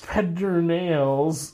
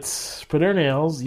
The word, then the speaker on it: Pedernales: Pedernales, but Pedernales. – It's all one word. Ales isn't its own word. It's part of the main word.